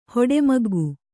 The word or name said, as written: ಹೊಡೆಮಗ್ಗು